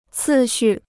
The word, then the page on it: 次序: cì xù